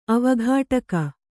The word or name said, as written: ಅವಘಾಟಕ